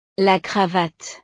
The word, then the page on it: la cravate: frances